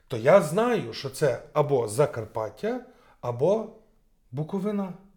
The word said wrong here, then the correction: Буковина: Буковина. Буковина, перепрошую. Буковина. Я знаю. З наголосом має вічну проблему.